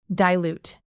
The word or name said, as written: dilute